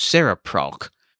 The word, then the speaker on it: CereProc: I also thought about buying one from, and I'll spell it, CerePROC, I guess it's pronounced Seraproc, which is the engine used by Microsoft's Soundscape app on the iPhone.